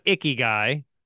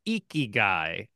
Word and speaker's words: Ikigai: And as a native speaker of the language pointed out, it's not Ikigai. That's not it. It's Ikigai.